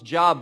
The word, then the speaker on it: Job: It's spelled J-O-B, not pronounced Job, but Job.